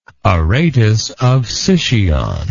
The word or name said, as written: Aratus of Sicyon